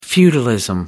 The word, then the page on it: feudalism: British English